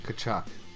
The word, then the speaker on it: Tkachuk: Florida was leading 4-0 when Brady Tkachuk, I never know how to say his name. – Tkachuk.